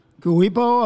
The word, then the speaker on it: Ku'uipo: His name is Kuipo. Did I say that right? – Kuipo Aloha.